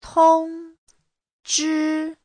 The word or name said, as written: tong1zhi1